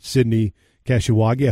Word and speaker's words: Sydney Kashiwagi: Sydney Kashiwagi. I hope I'm saying her name right. – You are.